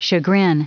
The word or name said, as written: chagrin